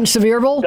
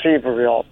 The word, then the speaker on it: Sevierville: I was recently in Seaverville. – You mean Sevierville? – Dolly. – Sevierville? – They pronounced it, when I read the booklet I had, they said it was pronounced Sevierville.